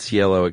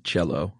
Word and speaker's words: Cielo: I looked up like three different places how to pronounce that, and they all said Cielo except for Diane Sawyer said cello.